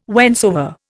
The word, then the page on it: whensoever: Indian